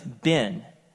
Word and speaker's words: Ben: And then, well, I'd say, well, my name is Ben.